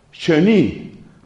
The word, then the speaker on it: Chenu: Si ustedes quieren saber más sobre cómo funcionaban esto de los comentarios, un padre dominico que se llama Marie-Dominique Chenu, se escribe C-H-E-N-U, de ahí tomo esto que les estoy diciendo.